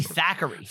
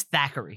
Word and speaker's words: Thackeray: It's actually Thackeray. – Thackeray. Is it actually Thackeray? – It's Thackeray.